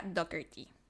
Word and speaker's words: Dougherty: Se escribe Daugherty, pero tengo entendido que se pronuncia Doherty.